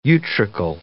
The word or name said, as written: utricle